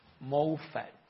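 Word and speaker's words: Moffatt: Por ejemplo, la traducción de how do you pronounce that word I always forget all the time? Moffat? Moffat. Ok, ya, yeah, Moffat. Lo dije bien.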